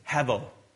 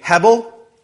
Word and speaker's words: hevel: It rhymes with level, but it starts with H. Hevel. So can you say Hebel? Yeah, it's kind of a B in the middle. A B, V, it's awkward, but let's say B.